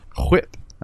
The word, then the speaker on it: WHIP: Nobody says WHIP. – WHIP. I believe that's how it's pronounced.